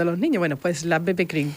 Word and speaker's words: BB Cream: Bebe cream. – Bebe cream, eso es.